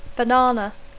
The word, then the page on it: banana: English